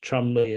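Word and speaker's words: Cholmondeley: The Oliver, so apparently the family name of Oliver Onions was pronounced Onions, which is like in Britain, if you have the family name Chumonly, it's actually pronounced Chumley, and there are numerous other things that, well, I mean, English is a much older language than we sometimes are aware of.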